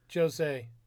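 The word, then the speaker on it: José: So Max Holloway and Showtime Pettis are going to headline that 206 card, and the winner will then face the paper champ, Josie Aldo. – Jose. – Yeah, there's some fighters that we just butchered their names. – Well, I thought you were purposely calling him Josie.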